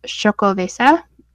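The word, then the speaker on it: Xocoveza: So I grabbed from Stone Brewery the I think it's pronounced Chocoveza. It's X O C O V E Z A.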